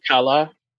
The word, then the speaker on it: Kalr: It sounds so like Mercy of Color when they say it, but I think it's like Mercy of Kala. – It's okay, yeah, it's spelled K-A-L-R, Mercy of Color. That's not a I can pronounce a lot of sounds, mm-hmm. but that consonant cluster is beyond me.